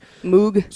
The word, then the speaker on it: Moog: It's spelled M-O-O-G. – Moog. – So you would think it's Moog, but it's pronounced Moog.